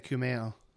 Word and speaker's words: Kumail: One is, Kamel, am I saying his name right? – Oh, yeah. – No. You're thinking of Kumail.